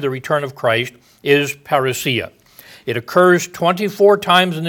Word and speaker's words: parousia: I was listening to a man on the radio who was talking about this, and he liked the word parousia, but he pronounced it parousia. And the more of the Greek pronunciation, parousia.